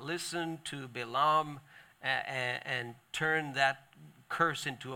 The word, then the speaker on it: Balaam: We call him Balaam sometimes. It's pronounced Balaam.